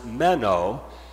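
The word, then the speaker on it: meno (μένω): Well, abide comes from the Greek word pronounced meno, and the word abide can mean remain.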